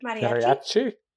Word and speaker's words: Mariachi: How do you say that word? – Mariachi. – Thank you. Yeah, both of you did it much better than I.